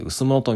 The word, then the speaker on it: 羅: 羅生門の羅と書いて薄物と読めます。